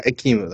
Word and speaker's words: Ekimu: Like the one that comes with Akuma, I think is this how you pronounce his name? The gold one, the small one. He come, Ekimu? – yeah, Ikimu, that's how you pronounce it.